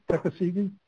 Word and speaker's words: Tuckasegee: Now, does the Davidson or the Tuckasegee, am I saying that right? – Tuckasegee, do those rivers have any of the warm water species, or are those strictly trout? – So the Davidson will.